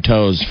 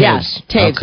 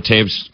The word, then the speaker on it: Toews: I called him Toes for like 2 straight years. – I'm not kidding. Like, you look at that and you're like, there's nothing to indicate that should be pronounced Toews.